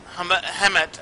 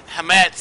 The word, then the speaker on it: chametz: For whoever eats chametz, I'm not saying it right today, chametz, thank you, from the first day until the seventh day, that soul will be cut off from Israel.